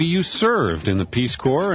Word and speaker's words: Corps: Visit the website World Service Corps, C-O-R-P-S, we would say Corps, but to help you spell it on the internet, worldservicecorps.us, to get more information.